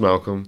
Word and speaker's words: MXLCXLM: Yeah, and it's pronounced Malcolm.